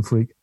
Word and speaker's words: Freke: And my name is an odd name. It's pronounced Freke, but it's written Freke. And as long as you get that right, you'll end up, there's only one Tim Freke, thank God.